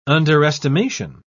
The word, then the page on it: underestimation: British English